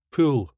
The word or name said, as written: pull